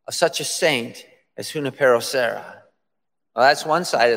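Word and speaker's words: Junípero: Family, today we celebrate, I'm in white, we celebrate the great Saint Junipero Serra. I've heard it pronounced Junipera, but we know who we're talking about.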